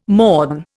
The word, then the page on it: morn: Indian